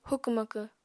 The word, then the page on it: hukw-makw: apatani